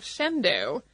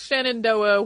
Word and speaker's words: Shenandoah: The other is that Kes included how to pronounce the town of Shendo, which is specifically spelled like Shenandoah, which is pronounced Shenandoah when used for other parts of the world in other places.